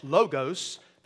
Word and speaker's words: logos: Now, David said it correctly in Greek. It is Logos. Now, the computer program you call Logos, that's in English. Because La is an Omicron. It's an O. It's not an Omega. If it were an Omega, we would say Logos. It's not. It's an Omicron O. Logos, with the short O, is how you actually say it in the Greek.